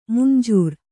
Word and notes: ಮಂಜರಿ: manjari

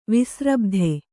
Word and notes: ವಿಸ್ರಬ್ಧೆ: visrabdhe

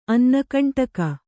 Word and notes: ಅನ್ನಕಂಟಕ: annakaṇṭaka

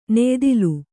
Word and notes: ನೆಯಿದಲೆ: neyidale; kannada

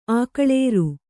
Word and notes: ಆಕಳೇರು: Kannada

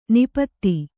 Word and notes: ನಾಪಿತ: nāpita

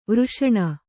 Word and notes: ವರ್ಷಣ: varṣaṇa

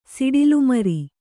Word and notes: ಸಿಡಿಲುಮರಿ: siḍilu mari

